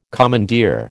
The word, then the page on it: commandeer: American English